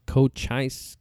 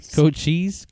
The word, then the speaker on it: Cochise: based his beliefs on what happened in Cochise County Sheriff's Office. – Cochise? – Cochise.